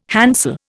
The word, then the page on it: handsel: Indian